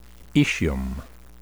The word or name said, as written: aecium